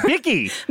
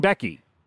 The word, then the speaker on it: Bicky: Is your name Becky, I'm realizing, but you pronounce it Bicky? – It's Bicky. So it's actually spelled B-I. – Oh, yeah, Bicky.